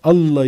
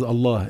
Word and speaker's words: Allah: The name of Allah in Arabic is Allah, is not Allah.